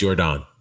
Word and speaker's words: Yordan: Is it Yordan or Yordan? – No, it's Yordan. – Yordan, I had it right the first time.